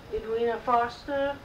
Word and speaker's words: Edwina Foster: Edwina Foster, E-D-W-I-N-A, F-O-S-T-E-R.